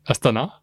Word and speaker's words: Astana: you do the same for Russia you corrected me several times on Astana.